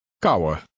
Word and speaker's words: kauwen: The W in this word adds a slight W sound at the end. Try to read number 2. – Kauwen.